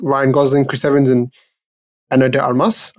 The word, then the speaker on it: Ana de Armas: You know, Ryan Gosling, Chris Evans, Ana de Armas, I think that's how you say her name. – that's how I say it, yeah.